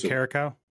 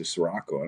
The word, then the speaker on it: Caracal: But anyway, that is limeade with, I don't know how to say it, blue. Somebody else help me out here. Blue Caracal? – Yeah, that one. I always want to say Sirocco.